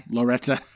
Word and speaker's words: Loretta: And I always say it like what's his name says it on Cheers. But anyway. – Loretta.